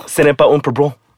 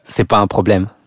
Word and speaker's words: C'est pas un problème: Ce n'est pas un problème. Is that, how do you say that? – C'est pas un problème.